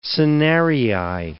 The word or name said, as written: senarii